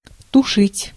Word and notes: put out: /pʊt aʊt/

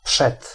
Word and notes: przed: [pʂɛt̪]

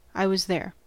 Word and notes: van: NL; /vɑn/

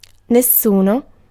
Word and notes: nessuno: /nes.ˈsu.no/

